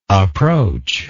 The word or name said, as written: approachless